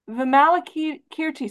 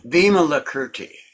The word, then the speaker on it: Vimalakirti: Vimalakirti said... – No, Vimalakirti. – Oh, Vimalakirti. Did I say that right? – Yeah.